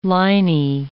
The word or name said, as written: liney